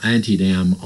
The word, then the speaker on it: Antietam: the Battle of Antietam on September 17, 1862. That's the southern pronunciation of the battle.